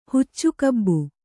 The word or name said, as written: ಹುಚ್ಚುಕಬ್ಬು